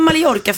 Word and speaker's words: Mallorca: Varför säger man Mallorca? För man ska ju inte uttala L. Man säger inte Pella, man säger Paella. Ja. Så jag ska säga Mallorca.